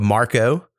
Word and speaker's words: Marcaux: who is an artist by the name of Marco, but it's spelled Marcaux.